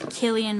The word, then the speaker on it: Cillian: Heck yeah, I love Cillian Murphy. I had to look it up to find out that you say his name Cillian, even though it's with a C.